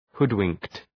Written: hoodwinked

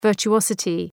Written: virtuosity

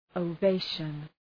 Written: ovation

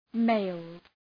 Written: mailed

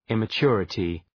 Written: immaturity

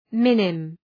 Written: minim

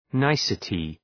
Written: nicety

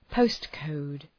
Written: postcode

